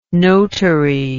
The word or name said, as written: notary